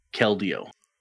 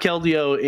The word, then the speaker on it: Keldeo: I think maybe my favorite mythical might have to be Keldeo. We don't have Keldeo in Pokemon Go just yet. – Are you saying the name right? – Yeah.